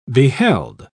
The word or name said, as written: beheld